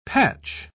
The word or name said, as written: patch